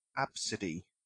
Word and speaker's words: Abcde: And apparently her name should be pronounced Absidy.